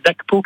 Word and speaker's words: Dhagpo: Bah, vous pouvez consulter à loisir le programme du centre Dakpo Kagyuling. Ça s'écrit D-H-A-G-P-O. – Ok. – Voilà, Kagyu, K. A-G-Y-U. – Entendu. – Ling, L-I-N-G.